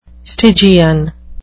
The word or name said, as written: stygian